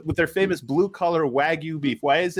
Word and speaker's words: wagyu: Did I say that right? Wagyu. – No, you did not. Wagyu. I did not. Okay. It's like wagyu.